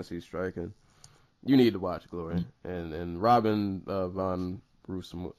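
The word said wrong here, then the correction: Robin van Roosmalen: I could keep butchering this dude's name. Robin von Roosmalen.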